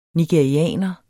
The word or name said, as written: nigerianer